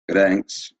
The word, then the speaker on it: Gdańsk: Gdańsk, yeah.